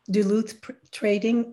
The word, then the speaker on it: Duluth Trading: And as a matter of fact, I thought I would buy from them the Duluth Trading. – Yep. – I don't know if that's how you pronounce it. – Yes. – Duluth Trading.